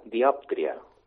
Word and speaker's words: dioptria: Ah, dioptries. – La paraula està bé, però la pronunciació no. – Tampoc. – És una dioptria. – Ostres, diàptria. – La síl·laba tònica sobre la O.